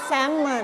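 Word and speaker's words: salmon: แซลมอน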